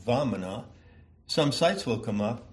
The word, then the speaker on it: Vamana: So I take the pronunciation of Vamana, where the V and the W, the V is close to a W, you catch that, from a YouTube channel which appears to be dedicated to a genuine and, what shall I say, authentic pronunciation of Sanskrit words, Vamana, obviously a word I enjoy saying.